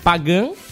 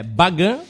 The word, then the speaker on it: Bagan: Pagan, ou Bagan, como também se pronuncia, é a antiga capital do reino de Pagan, que seria na Mianmar.